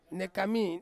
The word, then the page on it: nekami: Ansus